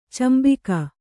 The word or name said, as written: ಚುಂಬಕ